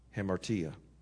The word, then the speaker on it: Hamartia: Hamartia, that's how you pronounce it.